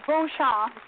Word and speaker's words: Beauchamp: Not quite. Beauchamp, but close enough. – What did I Beauchamp.